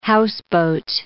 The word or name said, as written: houseboat